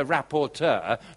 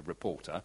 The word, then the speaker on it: rapporteur: that they sent a rapporteur, that is the correct way of pronouncing and spelling it, by the way, not just a reporter, a rapporteur, so one, a university professor, to come and look at the issue of poverty in this country.